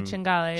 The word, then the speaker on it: cinghiale: We had tagliolino, cinghiale. Is that how you pronounce it? – Yeah, cinghiale. It's like boar, right? – Rigatoni Matriciana.